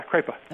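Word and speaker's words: Kreipe: What kind of presence did the general, is it pronounced Kripe? Is that how he said his name? – Yeah, Kripe.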